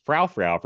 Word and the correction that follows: Frou Frou: for about 10 years of my life until someone said, I think it's Frou Frou. And I said, What? I've been saying Frau Frau for 10 years.